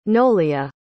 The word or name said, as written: nolia